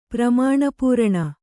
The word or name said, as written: ಪ್ರಮಾಣಪೂರಣ